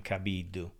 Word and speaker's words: Cabiddu: Gianfranco Cabiddu, but in Italian you pronounce my last name Cabiddu.